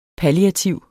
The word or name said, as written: palliativ